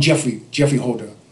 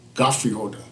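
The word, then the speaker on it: Geoffrey Holder: We all call him Jeffrey Holder. – Right. – Godfrey Holder, this is his real pronunciation.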